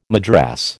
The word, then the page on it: madras: American English